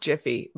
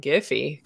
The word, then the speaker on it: Giphy: Although it's pronounced Jiffy, but.